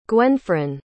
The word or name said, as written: gwenfron